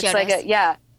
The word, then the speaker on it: Chiodos: Is it Chiodos? – Yeah. – Chiodos? It's Chiodos.